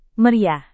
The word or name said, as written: meriah